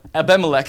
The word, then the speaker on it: Abimelech: How do you say the name? Abimelech? Abimelech. I'm just going to go with Abimelech. Abimelech. Pastor Brent corrected me on that.